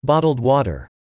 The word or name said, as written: bottled water